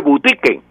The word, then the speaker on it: boutique: boutique se escribe boutique en francés, pero se pronuncia boutique en, en francés.